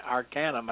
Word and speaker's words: Arcanum: in the small town of Acunum, Arcanum, I believe it's pronounced,